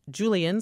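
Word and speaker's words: Julián: And here's an email from Peter, who says, how about Julian Soft J Castro for president?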